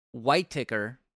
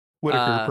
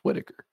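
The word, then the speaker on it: Whiteaker: We've got Jacob Yay Whiteticker. Whiteticker? – Whitaker, probably. – That's not how it's spelled. – I know. – I'm not shitting on you. I'm just saying it's probably pronounced Whitaker.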